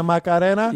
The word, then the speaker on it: Macarena: Macarena? – Yeah. – Yeah, you got to say it right there. Oh, Macarena. – Yeah, you of all people should be saying Macarena.